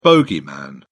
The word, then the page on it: bogeyman: British English